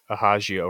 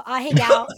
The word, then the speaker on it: ahegao: I thought it was like ahagio or something. – No, ahegao. It's, I mean, that's like... – Ahagio. – That's like sexy Japanese French. Mm-hmm. What's that? That's her ahegao face.